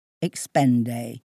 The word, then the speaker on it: Expenday: Not you, when you're using Expenday. Spell it E-X-P-E-N-D-A-Y, just like it sounds, and you'll be spelling a slick and simple system for keeping track of your expense account with just a few easy clicks.